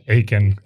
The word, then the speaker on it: Achan: His name was, I think you pronounce it in English, Achan? – Unfortunately, yeah, Achan.